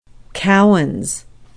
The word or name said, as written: keowns